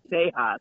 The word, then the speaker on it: Tejas: Ooh, Tejas. Nice. – Yeah. Tejas. As you said, it was a Bloomberg the other day. It's like it's pronounced Tejas.